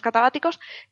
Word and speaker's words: catabáticos: Entonces, eh, casualmente en esta zona hay unos vientos, los vientos catabáticos, si lo he dicho bien, sí, los vientos catabáticos, que son bastante fuertes y tienen un flujo en sentido contrario a las agujas de reloj.